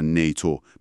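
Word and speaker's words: NATO: چرا مثلا نیتو مثل یه کلمه تلفظ میشه ولی FBI حرف به حرف.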